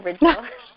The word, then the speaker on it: Ridgle: So my name is Mia Ridgle. I know it's hard to pronounce, but it's like Pringle, but with the R. So Ridgle.